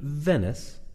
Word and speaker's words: Venice: I have traveled here all the way from a city in Italy called Venus. – You mean Venice? – Yeah, that's possible.